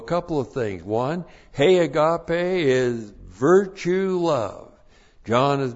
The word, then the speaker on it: agape: That's the definite article, hey, and then agape, A-G-A-P, long E, hey, agape.